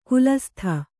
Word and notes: ಖಾಲಸಾತಿ: Kannada

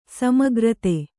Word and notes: ಸಮೀಕೃತ: samīkřta

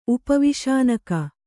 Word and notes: ಉಪವಿಷಾನಕ: Kannada

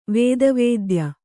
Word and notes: ವೇದವೇದ್ಯ: vēda vēdya; Kannada

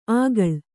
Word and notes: ಆಗಳ್: āgaḷ

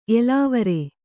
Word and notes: ಎಲಾವರೆ: elāvare

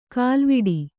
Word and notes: ಕಾಲ್ವಿಡಿ: kālviḍi

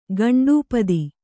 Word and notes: ಘಂಟಾಪಥ: ghaṇṭāpatha

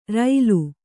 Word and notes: ರಳ: raḷa

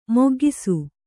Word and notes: ಮಗ್ಗಿಸು: maggisu

